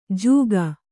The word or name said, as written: ಜೂಕ